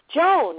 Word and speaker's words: Joan: I get John, I get J-O-N, I get J-O-N-E. – Oh, wow. – And I look, and I'm like, it's Joan.